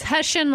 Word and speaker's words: Hussian: So I may mispronounce this. Hussian? – It's Hussian like Russian.